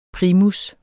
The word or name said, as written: primus